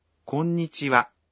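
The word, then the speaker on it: کونیچیوا (こんにちは): کونیچیوا اس میں بھی آخری وا کے متعلق یہی کہا جا سکتا ہے. اس کا تلفظ وا ہے لیکن اس کو لکھنے کا انداز ہا ہے.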